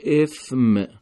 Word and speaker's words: اثم: کلمه اثم با ثا به معنای گناه هست. همین کلمه اگر با سین تلفظ بشه، یعنی اگر گفته بشه اسم، معنای نام میده.